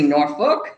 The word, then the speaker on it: Norfolk: And I'm in this study room, and it is Norfolk. That is how you say Norfolk. I'm from Jersey. That's how I say it. Tiffany correcting me.